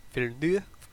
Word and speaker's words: Villeneuve: Denis Villeneuve? Or Villeneuve? – Villeneuve? – Cool. – Yeah, it's French. His last name is French, not Spanish.